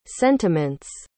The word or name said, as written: sentiments